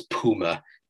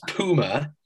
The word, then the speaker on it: Puma: Although I've got to say, the American pronunciation of Puma as Puma is absolutely ridiculous. Prince Puma... Pooh, just come on, get it together, America. What is wrong with you? Puma, the rest of the world knows how to pronounce it.